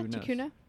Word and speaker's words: Tucuna: Kara is from the Tucuna Indian tribe. Did I say that right? – Yeah, Tucuna.